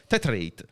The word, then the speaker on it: Tetrate: che si scrive come un comune qua della Brianza, Tetrate, però si pronuncia Tetrate.